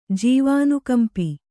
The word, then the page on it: ಜೀವಾನುಕಂಪಿ: jīvānukampi; Kannada